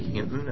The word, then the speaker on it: Hinton: My name is Larry Hinton, and my last name is spelled H-I-N-T-O-N.